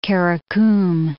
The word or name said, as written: Kara-Kum